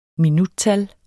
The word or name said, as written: minuttal